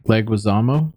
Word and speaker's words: Leguizamo: Leguizamo? – Yes.